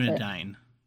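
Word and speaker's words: Dúnedain: Okay, so Morwen is a, correct me if I'm saying this wrong, Dunedain? I can't pronounce Dunedain.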